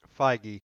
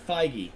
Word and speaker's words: Feig: F-E-I-G. I can never remember. – Feige. – Thank you. Okay, Feige.